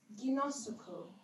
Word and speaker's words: ginosko: And this word to know in the Greek is ginosuko. – Nice. – Take my time to say that one. – Come on. – What does this mean? This is a vivid understanding. I'll spell it for you. G-I-N-O-S-K-O.